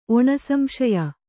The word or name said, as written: ಒಣಸಂಶಯ